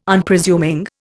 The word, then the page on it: Unpresuming: Indian